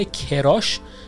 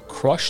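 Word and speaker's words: crush: با تلفظ اشتباه کراش خیلی اینو استفاده میکنن که کراش در واقع تلفظ باید بشه نباید بگید کراش که در واقع این تلفظ فارسی این کلمه هست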